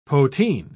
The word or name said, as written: poteen